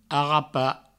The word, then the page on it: arrapar: arrapa